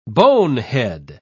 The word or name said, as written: bonehead